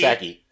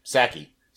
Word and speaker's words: Psaki: It was a, a press briefing uh, with how do you pronounce his press secretary? Is it Saki? Saki? Saki. – You pronounce the P. Saki. – Saki. Saki. The